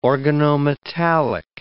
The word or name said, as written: organometallic